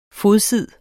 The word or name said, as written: fodsid